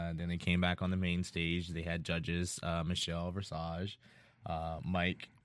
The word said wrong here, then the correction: Visage: Saying Versage. It's Visage.